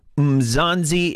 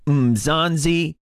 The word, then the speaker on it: Mzansi: Mzanzi is the code word. Again, Mzanzi, and it's spelled M-Z-A-N-S-I. Again, M-Z-A-N-S-I,